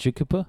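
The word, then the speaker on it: Tjukurpa: And we're probably mispronouncing with the Jukupa. – No, that's how you say it. – Jukupa? – Jukupa.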